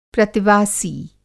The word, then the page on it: प्रतिवासी: Hindi